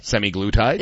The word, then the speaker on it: semaglutide: So a lot of people are taking too much semaglutide. – Semiglutide? – Is that how you say it? – Yeah.